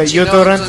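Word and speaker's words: uTorrent (µTorrent): La primera letra es una letra griega, ¿sí? – Sí, una MIU. – Se pronuncia uTorrent.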